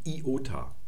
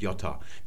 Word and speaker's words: Iota: Denn dieses I wird immer I ausgesprochen und niemals J. Also wenn man zum Beispiel Ionien sagt, das ist eine falsche Aussprache. Auch der Name dieses Vokals ist Iota und nicht Jota.